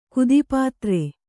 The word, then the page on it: ಕುದಿಪಾತ್ರೆ: Kannada